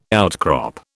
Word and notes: outcrop: British English

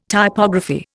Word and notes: Typography: Indian

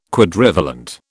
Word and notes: Quadrivalent: British English

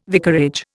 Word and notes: vicarage: Indian

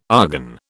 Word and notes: argon: British English

